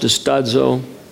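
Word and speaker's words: distazo (διστάζω): The Greek word for doubting is distazo, or distazo is the way it looks, pronounced distazo, means to stand divided.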